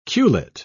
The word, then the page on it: culet: British English